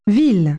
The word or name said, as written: town